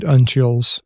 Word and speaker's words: uncial: Your article primarily focused on four of these codices, the great uncials. Uncials, am I saying that right? – I would say uncial. – Uncial, okay, we'll go with that.